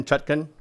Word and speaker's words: Chutkan: Uh, Chutkan, I think is how you say it. C H A T K U N, Chutkan, right?